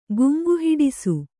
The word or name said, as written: ಗುಂಗುಹಿಡಿಸು